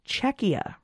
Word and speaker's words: Czechia: According to a patron who is in the Czech Republic is still a valid name, but the pronunciation is Czechia.